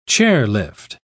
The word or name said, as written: chairlift